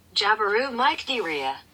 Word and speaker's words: Mycteria: Jabiru Mycteria.